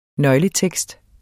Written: nøgletekst

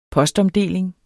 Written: postomdeling

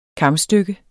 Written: kamstykke

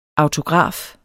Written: autograf

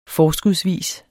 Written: forskudsvis

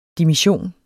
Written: dimission

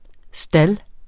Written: STALDENES

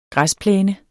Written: græsplæne

